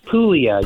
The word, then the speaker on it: Puglia: Now, I'm going to ask you to, it's a night in, is it Puglia? – Puglia. You don't pronounce the G.